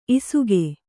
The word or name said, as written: ಇಸುಗೆ